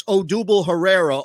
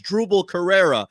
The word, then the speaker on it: Odubel Herrera: He knows how to pronounce Odubel Herrera or as Drubel Carrera.